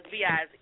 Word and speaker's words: B.I.Z.: Yeah, B.I.Z. Yeah, he's weird. Yes, King okay. – B.I.Z. – Okay, all right. So everybody out there listening in, please pardon me. I was always saying B.I.Z., but okay.